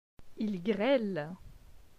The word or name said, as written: Il grele